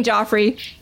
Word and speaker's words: Joffrey: His name starts with J and it rhymes with Offrey. And all right, it's King Joffrey.